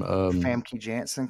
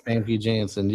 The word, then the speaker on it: Famke Janssen: Famke Janssen, because she's in one of my picks. – Famke Janssen.